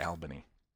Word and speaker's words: Albany: It's spelled Albany, but everybody pronounces it Albany.